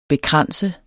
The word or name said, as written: bekranse